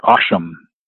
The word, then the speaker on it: Occam: More like Occam. – Occam, okay, great. – Yeah, even though it looks like it's a double C, it's a hard C sound, they actually say Occam's razor.